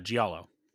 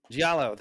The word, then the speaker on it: Giallo: Giallo. – Giallo, thank you.